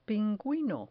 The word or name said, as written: pinguino